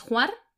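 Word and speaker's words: Hvar: Vamos a empezar a hablar de la primera que hemos nombrado, que es Juar, ¿vale? Que nos costó bastante conocer la pronunciación, pero bueno, es una de las islas, corrígeme si me equivoco, más grandes de toda Croacia.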